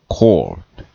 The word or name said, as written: court